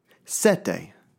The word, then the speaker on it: sette: Sette.